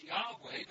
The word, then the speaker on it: Yahweh: We pronounce it now as Yahweh, but they would only write those letters out.